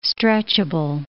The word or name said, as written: stretchable